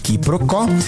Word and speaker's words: quiproquo: Q-U-I-P-R-O-Q-U-O com acento agudo. Quiproquo, também informalmente pronunciada como quiproquó, é a ação de confundir uma coisa com outra, ou o desentendimento decorrente dessa confusão.